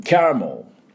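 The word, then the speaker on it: caramel: キャラメルはですね、どんな音になるかというと、キャラメル、キャラメル。はい、これキャラメルのキャーのところがアクセントがありまして、おみゃーですね。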